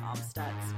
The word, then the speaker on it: Amstutz: You can see all those pictures I'm taking on all social media platforms at Sandra Amstutz. My last name is spelled A-M-S-T-U-T-Z.